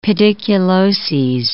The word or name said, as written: pediculoses